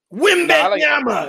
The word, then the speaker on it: Wembanyama: Wembanyama. That's how you say it on 2K. That's my guy. – What bananas? Oh yeah, Kevin Harlan be glazing on 2K. Bro. Oh my god. – Wembanyama.